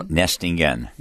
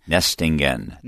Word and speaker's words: Nestingen: No, Nestingen. – Say it again. – Nestingen.